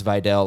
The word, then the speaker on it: Vidal: I keep saying Vidal, which is, by the way, how I do believe it is pronounced. You might be looking at the spelling and going, you're an idiot, but I heard it pronounced Vidal like 100 times, so I'm rolling with that.